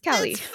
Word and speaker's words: Kali: And so this is – it's spelled K-A-L-I, right? – Yes, Kali.